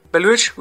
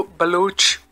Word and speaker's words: Baluch: Baluch would be the proper pronunciation, but there is also Baloch, Baluch, Balosh, or Balok if you're an old English person.